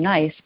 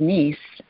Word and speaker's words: Nice: Yeah, it was a long time ago, but when I was growing up, we had cookies called Nice cookies, and we used to pronounce them Nice, but they were actually named after the city of Nice, and that was my earliest introductions to the existence of this place with palm trees that I now live in.